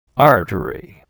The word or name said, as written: artery